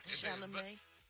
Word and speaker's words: Chalamet: Oh, that's how you say it. – Yeah, Chalamet. – Chalamet. – You was close though. You say you ever thought about going back? You ever thought about going back to kindergarten? – It's like Chevrolet, not Chevrolet.